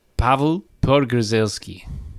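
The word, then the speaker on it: Paweł Podgórzelski: It's Pavel Podgorzelski. – One more time. – Well, it's spelled P-A-W-E-L, but I think it's a V. Pavel. Pavel Podgorzelski.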